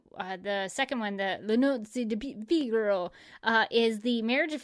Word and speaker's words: Le Nozze di Figaro: Le Nozze di Figaro is how they pronounce it in the uh, uh, website here.